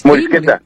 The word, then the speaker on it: morisqueta: A ver, ¿podrías decir a nuestra gente que es la moristeca, morisqueta? – Morisqueta, sí, ya morisqueta, ves, ni sé pronunciarlo porque no soy de aquellos rumbos.